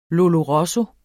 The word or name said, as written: lollo rosso